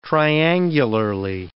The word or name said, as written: triangularly